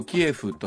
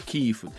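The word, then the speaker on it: キーウ: のキエフというもともと今まで我々も発音していた、ね、首都キーフ、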